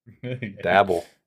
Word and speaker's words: Daboll: Daboll.